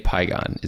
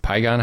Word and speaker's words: Pygon: In today's episode, we have Dave Pygon. Is Pygon how you pronounce it? – Yes.